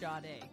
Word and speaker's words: Sade: Spelled Sade, but let me tell you, it is pronounced Sade.